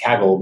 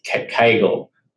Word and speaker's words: Kaggle: I was from Australia where it's pronounced Kaggle, but in America, It's pronounced Kaggle, which sounds like a pelvic floor exercise.